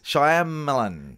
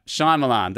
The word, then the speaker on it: Shyamalan: Shyamalan. – Yeah, Shyamalan, the guy who made The Sixth Sense.